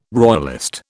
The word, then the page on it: royalist: British English